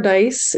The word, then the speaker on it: Dyce: I'm Amber Dyce. It's spelled D-Y-C-E, and I'm the CEO of the Foundations for Social Change.